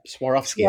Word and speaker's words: Swarovski: How do you pronounce that? Swarovski? – You got that right. Yeah, Swarovski.